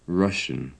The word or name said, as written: russian